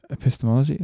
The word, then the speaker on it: epistemology: Yeah, it's a different epistemology. Am I saying that right? It's a different way of knowing things. – You're saying that right.